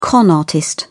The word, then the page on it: con artist: British English